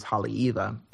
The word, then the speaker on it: Haleiwa: And same like the town that I'm from that I pronounce as Haleiwa. It's spelt like Haleiwa. But if the W is in the middle of the word, it's actually a V, kind of like V spot.